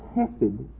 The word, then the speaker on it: hesed: The word hesed is a word that, when you add the plural on it, becomes the godly ones of verse 23.